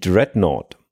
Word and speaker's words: Dreadnought: Im Rahmen dieser Materialschlacht entwickelt die britische Marine die Dreadnought. Ein bahnbrechendes neues Schiff. Oder spricht man es anders aus, Dominik? – Nein, alles gut.